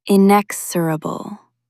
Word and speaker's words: inexorable: In, ick, sir, a, bull. Meaning, relentless, unstoppable, and impossible to turn aside. Latin roots mean unable to be moved by pleading. Nothing can talk it out of moving forward. Think of the inexorable tick of the clock. Stress the second syllable, inexorable. Say it out loud, inexorable.